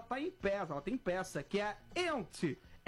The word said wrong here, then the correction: Yentl: Ente in Concert. – Yentl. – Yentl. Perdão, eu vou fazer com pronúncia Yentl in Concert.